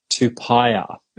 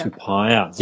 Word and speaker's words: Tupaea: Tupaia. – Tupaia, yeah. T-U-P-A-E-A as pie, so Tupaia. – Yeah. Tupaia.